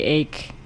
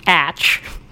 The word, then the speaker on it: ache: And so now we say ache no matter what. But if you want to be a purist, you should be pronouncing the atch that you have in your muscles is aching today.